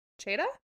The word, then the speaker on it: Csejthe: Cheda. Cheda? – Yeah. – Cheetah Castle. It's spelled C-S-E-J-T-H-E.